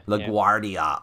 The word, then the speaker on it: LaGuardia: LaGuardia. – Oh, what am I saying? Yeah, of course, like the airport.